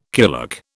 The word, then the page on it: kulak: British English